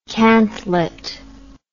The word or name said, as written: cantlet